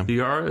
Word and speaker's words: Vergara: And voiced by Sofia Vergara? – Yeah. – Vergara, is that how you say it? – Yeah.